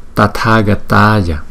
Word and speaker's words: Tathagataya: Tathagataya, Tathagataya, Tathagataya. Chữ T với chữ H chúng ta đọc là tờ hờ tờ tờ tờ.